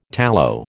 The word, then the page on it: tallow: American English